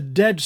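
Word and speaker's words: de (δέ): So but is a dead stop, whereas and continues with the next step. But the Greeks have another word in the middle, de, it's pronounced, which is sort of kind of halfway ground.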